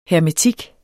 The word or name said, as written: hermetik